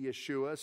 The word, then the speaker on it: Yeshua: And, of course, if you're going to say his name in Hebrew today, it'd be Yeshua.